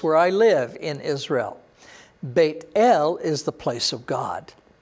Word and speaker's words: Beit El: And he moved from there to the mountain east of Beit El. Now, your Bible may say Bethel. Learn to pronounce that as Beit El.